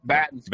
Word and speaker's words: Battin: Perry Battin, right? Did I say that right? Perry Battin, or is it Perry Battin? – Nope, Battin's correct.